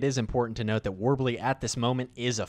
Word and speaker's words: Worbli: and it's called Warbly. I hope I said that right. – Yeah, Warbly, W-O-R-B-L-I.